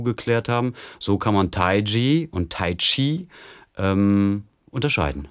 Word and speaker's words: Taiji: Aber Tai Chi ist die richtige Aussprache.